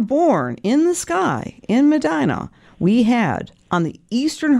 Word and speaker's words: Medina: And you were born in Medina, which rhymes with Regina in Saskatchewan.